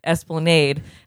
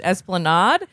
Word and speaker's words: Esplanade: And he describes an early show where he said, I played a song called On the Esplanade, but pronounced it Esplanade. And some guy called out, it's pronounced Esplanade.